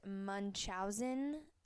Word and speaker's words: Munchausen: Um, I now know that it is pronounced Munchausen, so just wanted to correct myself.